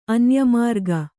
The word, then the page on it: ಅನ್ಯಮಾರ್ಗ: anyamārga